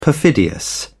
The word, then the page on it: perfidious: British English